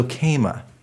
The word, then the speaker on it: Kama: So Kama.